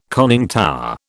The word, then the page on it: conning tower: British English